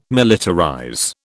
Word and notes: militarize: British English

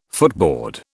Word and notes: footboard: British English

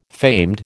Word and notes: famed: American English

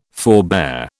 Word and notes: forebear: British English

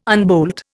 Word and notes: unbolt: Indian

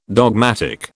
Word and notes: dogmatic: British English

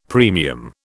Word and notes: premium: British English

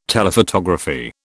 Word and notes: Telephotography: British English

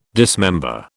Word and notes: dismember: British English

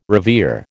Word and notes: revere: American English

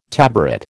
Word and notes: tabaret: American English

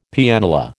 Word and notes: Pianola: American English

